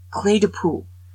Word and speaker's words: Clé de Peau: Okay, I learned how to pronounce it. Clé de Peau.